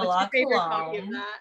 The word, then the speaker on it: La Colombe: It's like the La Colombe. Is that how you say it? – Oh my gosh. Yes. The La Colombe.